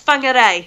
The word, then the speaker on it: Whangarei: But is it Whangarei? Whangarei? – That was close. Whangarei.